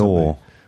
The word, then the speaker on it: gnaw: There's a verb that people won't know, to gnaw on something. – Gnaw. – Well, it's G-N-A-W. – Yeah, you don't pronounce the G. – And it's pronounced gnaw.